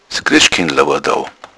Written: christ

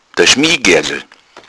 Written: schmiehgergl

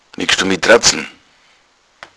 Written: tratzen

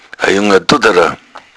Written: duddara

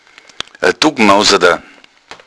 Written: duckmousad

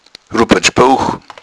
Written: rupertsbuch

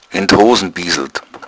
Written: bieseln

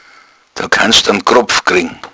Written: kropf

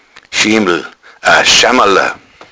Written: schammala

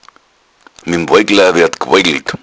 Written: woigla

